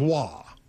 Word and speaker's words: Roy: Yeah, R-O-Y, but it's pronounced Wah.